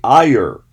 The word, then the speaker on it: Iyer: Pico Liar, I believe, Iyer, I believe is how you say this person's name.